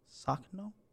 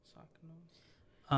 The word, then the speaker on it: Sakhno: Sakano. Sak Sak Sakhno. – Sakhno.